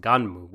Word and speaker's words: Gunnm: G-U-N-N-M, pronounced Gunmu,